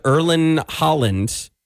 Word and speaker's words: Erling Haaland: Tom Holland, aka Spider-Man, apparently DM'd Erling Haaland.